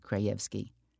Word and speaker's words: Krajewski: even though he preferred the Polish pronunciation Krajewski.